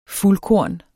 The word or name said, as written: fuldkorn